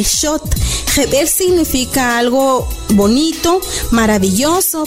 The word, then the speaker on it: xot: Para decir comal, decimos shot. Shot se escribe X-O-T.